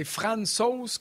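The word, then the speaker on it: Francouz: Le gardien de l'Avalanche du Colorado, Pavel, c'est Fran Sauce comme dans Poutine sauce à la viande comme sauce à spaghetti.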